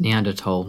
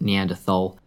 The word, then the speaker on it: Neanderthal: And yes, that's how that's actually pronounced. It's Neanderthal, not Neanderthal. It's German.